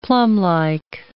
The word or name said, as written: plumlike